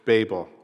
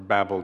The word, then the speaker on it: Babel: The story in Genesis, the Tower of Babel, or Babel, depending on your pronunciation, you can see symmetry in the story.